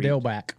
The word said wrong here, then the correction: Dettelbach: What'd you say? – Steven Dalback? That guy? – Dettelbach. That's it.